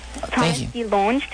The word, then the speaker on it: DeLonge: it's DeLong, but okay. That's only because they started mispronouncing it in my family. It's supposed to be DeLonge or DeLonge.